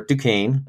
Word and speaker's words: Duquesne: We had some fun with you trying to pronounce Duquesne or Duquesne, as it's pronounced.